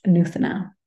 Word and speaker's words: Nuthana: This is how you actually pronounced it. It's pronounced Nuthana, and it means new in Sanskrit.